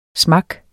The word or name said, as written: smak